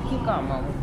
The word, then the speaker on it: хикама: Здесь и ассортимент, и вот мы сейчас поищем, кстати, есть ли у них хикама, который... – Хикама, Света. Хикама. Ударение на, хикама.